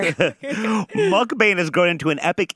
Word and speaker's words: Mukbang: Mukbang? – Mukbang.